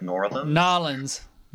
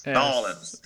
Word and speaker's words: New Orleans: I've always pronounced the name of the city New Orleans, but I guess it's more like Nolens. – Yeah, but yeah, when you're in New Orleans, it's Nolens, N-A-W-L-I-M-S. Nolens. And I've got to say,